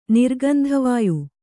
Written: ನಿರ್ಗಂಧವಾಯು

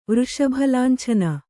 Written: ವೃಷಭಲಾಂಛನ

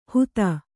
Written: ಹುತ

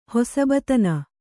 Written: ಹೊಸಬತನ

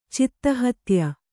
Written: ಚಿತ್ತಹತ್ಯ

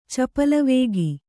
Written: ಚಪಲಾವೇಗ

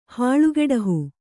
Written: ಹಾಳುಗೆಡಹು

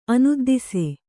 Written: ಅನುದ್ದಿಸೆ

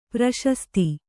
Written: ಪ್ರಶಸ್ತಿ

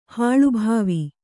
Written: ಹಾಳುಭಾವಿ

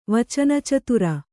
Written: ವಚನಚತುರ

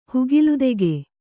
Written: ಹುಗಿಲುತೆಗೆ

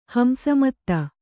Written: ಹಂಸಮತ್ತ

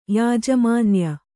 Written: ಯಾಜಮಾನ್ಯ

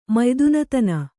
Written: ಮಯ್ದುನತನ

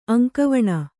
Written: ಅಂಗವಾನು

